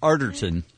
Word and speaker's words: Arterton: Arterton.